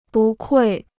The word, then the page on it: 不愧: bù kuì